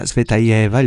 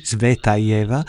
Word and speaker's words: Tsvetaeva: Sì, la musica è dedicata alla poetessa russa Marina Tsvetaeva, il nome si pronuncia così, Tsvetaeva.